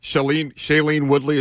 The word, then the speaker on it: Shailene Woodley: Shailene Woodley, is that how you pronounce her name? – Yes.